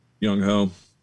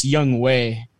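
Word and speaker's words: Younghoe: Young Ho. – Young Ho. – Young Hoku. Yes, sir. – Young Ho. – Well, I think it's Young Way. Is how you actually say it, but it's spelled.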